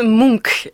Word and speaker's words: Munch: Comment on prononce le nom de cet artiste ? – On prononce Munch. – Munch, comme Télénews Munch. – C'est ça, il faut s'y habituer, mais en fait, finalement, c'est assez simple.